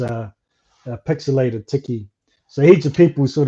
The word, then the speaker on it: Tichi: But I named it Tiki, T-I-C-H-I, and it's pixelated. So if you spell the word T-E-C-H, you come up with tech. So you still pronounce it Tiki.